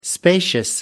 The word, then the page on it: spacious: American English